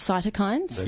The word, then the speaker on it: cytokines: Is that how you pronounce cytokines? – Yep. Mm-hmm.